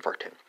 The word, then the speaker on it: warten: Dutch infinitive is spelled W-A-C-H-T-E-N. German infinitive is spelled W-A-R-T-E-N. Spelled respectively, Wachte and Varten. Or pronounced respectively, Wachte and Varten.